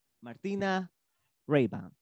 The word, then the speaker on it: Martyna Reiban: Es por ello que decidimos invitarla hoy en nuestro podcast con ustedes, Martina Rayban. ¿Es verdad? ¿Lo pronuncié bien? ¿Mal? – Rayban. – perfecto.